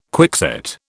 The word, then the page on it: quickset: British English